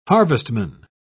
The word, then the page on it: harvestman: British English